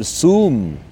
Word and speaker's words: Zoom: Me está llamando por Zoom me está llamando por Zoom en inglés, por Zoom en español. No pronunciamos excesivamente la letra Z en español cuando nos referimos a esta aplicación tecnológica para hacer videollamadas.